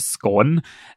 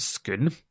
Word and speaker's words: Scone: I kept calling it Scone, thinking that's what the village was called because that's how it's spelt.